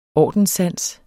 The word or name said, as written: ordenssans